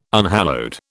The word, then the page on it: unhallowed: British English